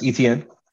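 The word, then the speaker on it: Etienne: Travis ETN.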